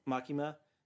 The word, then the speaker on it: Makima: So we have Ryan Colt Levy as Denji, Susie Jung as Makima? – Makima.